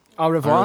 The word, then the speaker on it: au revoir: Adewa? – French it? – Stupid. French it? – No, Adewa. No. How do you say, how do you say goodbye in French? – Au revoir. – Au revoir.